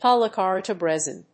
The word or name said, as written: polycarbonate resin